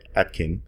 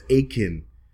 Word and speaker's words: Atkin: There's some video assets that will be coming out where I mistakenly pronounced her surname as Akin. – I keep thinking that it's Akin too, and it's not.